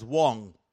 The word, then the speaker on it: Wang: My name is Solomon Wang, pronounced as Wong. I'm not kidding you. It's W-A-N-G, absolutely true, pronounced as W-O-N-G. And if you say Wang, that really would be Wong.